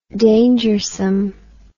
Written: dangersome

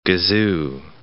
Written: gazoo

